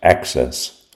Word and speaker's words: AHCCCS: Arizona's Medicaid program is called the Arizona Health Care Cost Containment System, or AHCCCS, pronounced ACCESS.